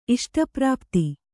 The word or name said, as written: ಇಷ್ಟಪ್ರಾಪ್ತಿ